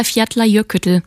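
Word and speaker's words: Eyjafjallajökull: Ja, Fjallajökull.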